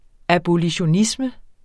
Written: abolitionisme

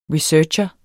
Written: researcher

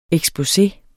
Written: eksposé

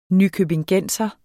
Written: nykøbingenser

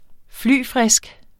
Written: flyfrisk

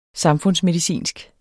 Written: samfundsmedicinsk

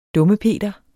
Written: dummepeter

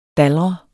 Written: dalre

